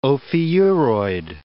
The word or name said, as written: ophiuroid